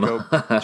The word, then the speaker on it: Schoop: They go and get Jonathan Schoop, or how do you say it? Scoop from Scope. Jonathan Scope. – Scope, thank you, from the Baltimore Orioles.